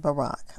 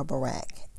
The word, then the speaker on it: Barak: Again, we're looking at Judges chapter 4, verses 8 through 10 in the New King James Version, which titles it Deborah and Barak, or Barak. I've heard it pronounced two different ways depending on which version I listen to.